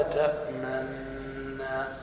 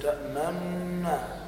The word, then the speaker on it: تأمنا: عندك هنا تجد على هامش المصحف يجب الروم أو الإشمال في كلمة تأمنا، والإشمال هو أن تنطق النون كمن يريد أن ينطق بالضم دون أن يظهر لذلك أثر في النطق، فتقول ما لك لا تأمنا، وليس تأمنا قبل النطق بالإشارة بالضم، بحيث أن الأعمى لا يرى فرقا.